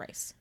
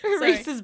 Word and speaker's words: Rhys: I mean, I still just call him, it's Rhysand, but then when it's a nickname, it's Rhys. – Rhys, I don't call him Rice.